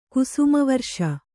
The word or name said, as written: ಕುಸುಮವರ್ಷ